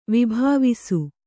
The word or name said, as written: ವಿಭಾವಿಸು